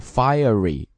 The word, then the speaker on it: fiery: 那么我们首先来看到第一句， In a fiery debate between the Republican contenders for the American presidency，在这个非常激烈的辩论当中，这个辩论这个词叫 debate 那前面有一个词叫 fiery fiery，注意它的那个发音那个 I 字母就读它的字母音， a fiery debate 一个非常激烈的辩论当中。